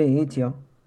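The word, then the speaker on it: itch.io: Sí, Ichio. – Vale. Que es ilatina T de, de tierra C H punto I y latina O. Itch y punto io.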